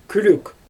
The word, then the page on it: kürük: Xyzyl